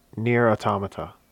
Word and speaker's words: Nier Automata: But you, I have purchased but have not played yet the game, I might be mispronouncing it, Nier Automata.